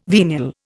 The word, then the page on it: venial: Indian